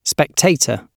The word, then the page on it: spectator: British English